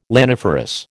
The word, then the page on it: laniferous: American English